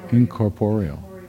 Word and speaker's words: incorporeal: How do you pronounce that? – Incorporeal. – That's my problem. I was accenting the wrong syllable.